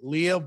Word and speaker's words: Leah: It's some people say Leia, like Princess Leia, but I don't know how to correct them, but no, it's Leah, but it's good.